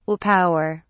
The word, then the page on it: will power: British English